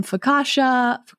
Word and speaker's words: focaccia: They have pizza, they have pasta, they have breadsticks and focaccia, is how you pronounce it, or bruschetta.